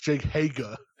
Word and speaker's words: Hager: Yeah, you're not saying it right. It's Jake Hager.